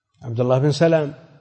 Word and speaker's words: عبد الله بن سلام: لابن سلام من الحبر والمعتزلي، لابن سلام من الحبر، الحبر كان من أحبار اليهود، عبد الله بن سلام، فأبوه بالتخفيف سلام، عبد الله بن سلام، وهذا ما اختلف فيه أحد.